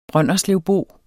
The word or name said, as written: brønderslevbo